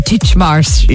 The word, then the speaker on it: Titchmarsh: Is that how you pronounce his name? Titchmarsh. – Yeah, Alan.